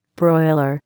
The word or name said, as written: broiler